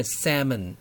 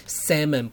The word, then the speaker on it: salmon: 各位同学好，你现在所收听的是 Podcast 耳朵学英文，今天要学的是鲑鱼。鲑鱼的英文很多人都不会念，注意它是 S-A-L-M-O-N，但是 L 不发音啊，所以它是念 Salmon， Salmon 不是 Salmon，你要特别注意，也不是 Slamon。